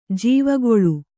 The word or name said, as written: ಜೀವಕಾಲ